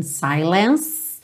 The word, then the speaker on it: silence: In silence. Termina nesse som do C. Não vai pro E. O E é mudo, ok?